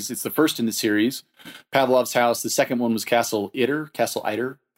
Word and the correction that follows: Itter: I'm sure I'm mispronouncing that. Itter.